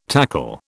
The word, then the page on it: tackle: British English